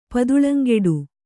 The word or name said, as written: ಪದುಳಂಗೆಡು